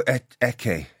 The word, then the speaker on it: Ecce: Do you go ecclesiastical with the soft C, or do you go with the hard C? – I go Ecce. – Ecce. – So it's Latin more than Italian, yeah.